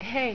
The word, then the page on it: Hei: Finnish